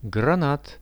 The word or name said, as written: granat